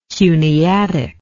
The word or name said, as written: cuneatic